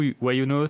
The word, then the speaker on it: YunoHost: Yunos, ça s'écrit Yunohost.